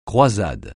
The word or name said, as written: croisade